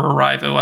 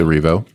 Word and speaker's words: Erivo: And a lot of that heart comes from Cynthia Erivo, or Arrivo. I don't know how to say her last name. Sorry. – It's Erivo, but...